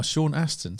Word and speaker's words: Sean Astin: There, Sean Astin, Sheen Astin. – Oh, Sean Astin.